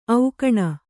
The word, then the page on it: ಓಖಣಿ: ōkhaṇi